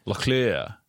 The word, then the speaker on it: Locklear: Locklear. – Yeah, that's the correct pronunciation.